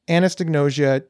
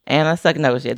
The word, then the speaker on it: anosognosia: We have done such a great job of bringing anosognosia to the forefront of getting, did I pronounce it wrong? – Anosognosia. There's no T.